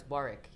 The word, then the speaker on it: Warwick: I think I was 18 or something, and I pronounced it incorrectly, said no, it's Warwick, you know.